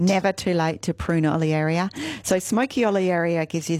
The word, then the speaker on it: Olearia: I have some stunning little smoky olearia.